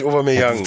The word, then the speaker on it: Aubameyang: Ich, keine Ahnung, Aubameyang, wird da wirklich Aubameyang ausgesprochen? – Ich habe den Aubameyang mal ausgesprochen. – Ja, der wird Aubameyang.